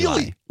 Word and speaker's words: Ely: I will say, though, Phil in Minneapolis just called in and says it is pronounced Ely. – Oh, really? – Eli.